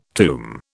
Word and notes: tomb: British English

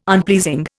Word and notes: unpleasing: Indian

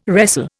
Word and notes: wrestle: Indian

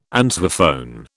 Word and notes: answerphone: British English

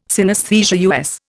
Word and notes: synesthesia: Indian

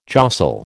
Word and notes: jostle: American English

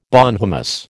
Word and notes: bonhomous: American English

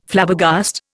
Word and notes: Flabbergast: Indian

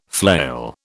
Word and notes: flail: British English

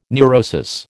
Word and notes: neurosis: American English

